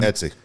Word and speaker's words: Etsy: Or on, is it Etsy? I always say that wrong. – Etsy. – V. Gomez Marin. M-A-R-I-N.